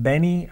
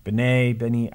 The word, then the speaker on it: Benne: Benny, I think is how you say it. B-E-N-N-E. Benny.